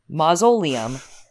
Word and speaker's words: mausoleum: He had seen the word, though, never heard it, and he pronounced it to rhyme with linoleum. You know, mausoleum.